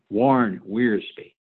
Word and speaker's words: Wiersbe: But I want to read to you in closing what Warren Weir, let me say it right, Warren Wiersbe wrote about Abraham's visit in Gerar here in chapter 20.